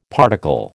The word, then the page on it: particle: American English